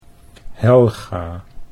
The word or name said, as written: Helga